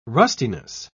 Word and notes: rustiness: British English

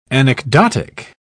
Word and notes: anecdotic: British English

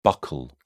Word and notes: buccal: British English

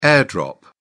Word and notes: air-drop: British English

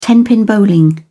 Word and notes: tenpin bowling: British English

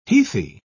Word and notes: heathy: British English

